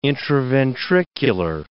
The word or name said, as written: intraventricular